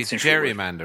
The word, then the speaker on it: gerrymander: Gerrymander is an early, it's weird that we say gerrymander, word. Right? Because his name was Gary. – Gary, yeah, but anyway. It just looks like it should be pronounced Jerry.